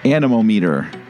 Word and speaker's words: anemometer: It's a anemometer.